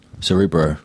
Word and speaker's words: Cerebro: So that Cerebro, am I saying that right? – Cerebro. – Yeah, it's, yeah.